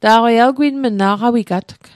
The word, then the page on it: aqawigatg: aqawikatk